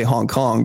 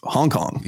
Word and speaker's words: Hong Kong: What about people who put, you know, the emphasis on different syllables with regard to Hong Kong? Because I say Hong Kong, but, you know, in the United States, it seems to be predominantly pronounced Hong Kong.